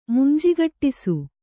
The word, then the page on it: ಮುಂಜಿಗಟ್ಟಿಸು: munjigaṭṭisu; Verb